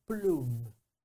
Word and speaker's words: Plume: Que no es otra cosa que un acuerdo que Liberty hizo con una compañía que se llama Plum. Plume se escribe, Plume.